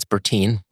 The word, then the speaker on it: Bertin: We have Kyle Burton. – Yeah. It's Bertine.